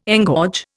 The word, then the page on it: engorge: Indian